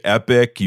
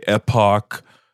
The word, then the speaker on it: epoch: First off, how do you pronounce it? Some people say epic. You say epoch. – Epoch. Yeah. Epoch, right? Epoch. – When I say Epic Times, it reminds me of the website that Jerry Doyle used to have, which was EPIC, Epic. But is that how you pronounce it? Is it epic or epoch? – I say epoch. You want to do it Hebrew style, then epoch.